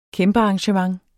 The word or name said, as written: kæmpearrangement